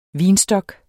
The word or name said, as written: vinstok